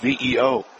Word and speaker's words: Viau: Avio steps in, spelled V-I-A-U, pronounced V-E-O.